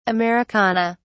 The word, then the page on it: americana: British English